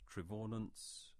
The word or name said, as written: Trevaunance